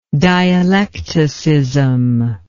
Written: dialecticism

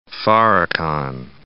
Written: Farrakhan

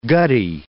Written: gutty

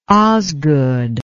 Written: Osgood

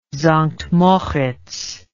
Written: Sankt Moritz

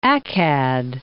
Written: Akkad

